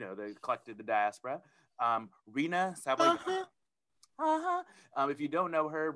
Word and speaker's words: Rina Sawayama: Rina Sawayama. – Sawayama. Rina Sawayama.